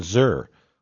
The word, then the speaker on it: Szczur: Jim Shaw's father-in-law, Julian Zur, was S-Z-C-Z-U-R. – There's two Z's? – There's two Z's. – They cancel each other out. – But it sounds like you're pronouncing one of them. – Yeah. – Well, it was just Zur. Well, it's true. It sounds like Zur. – The S might be silent. – It sounded there. – Like Z-U-R-R.